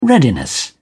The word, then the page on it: readiness: British English